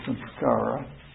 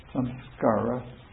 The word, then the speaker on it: samskara: The Sanskrit word for it is samskara. Samskara. – Do you want to spell that for the tape? – Samskara. S-A-M-S-K-A-R-A.